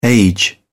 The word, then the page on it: age: British English